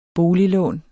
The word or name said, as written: boliglån